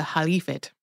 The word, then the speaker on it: caliphate: I feel like we have always said this either as caliphate or caliphate on the show. They take a more Arabic pronunciation in this video game, and so it's always like Khalif, the Khalifate.